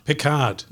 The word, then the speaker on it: Picard: Picard.